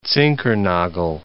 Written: Zinkernagel

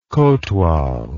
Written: kotwal

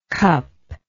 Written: cuplike

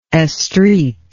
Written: estreat

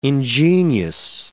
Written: ingenious

